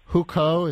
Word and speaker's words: Hukou: And one problem for these migrants that you write about is the Hukou, is that how you say the word? – Yes, that is how you say the word.